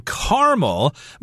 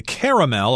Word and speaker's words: caramel: And we should mention that this is one of those particular words that have different ways to pronounce it depending on the person, depending on where they're from, etc. I grew up saying caramel, but I know some people say caramel or caramel.